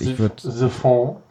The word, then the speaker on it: Siphon: Siphon, Siphon. Ich würde später betonen.